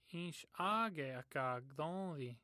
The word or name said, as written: gthonthin